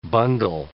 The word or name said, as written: bundle